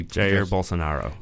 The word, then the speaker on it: Jair Bolsonaro: Jair Bolsonaro.